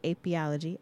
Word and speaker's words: apiology: She's dedicated to educating children to not only learn about apiology, I hope I said that right.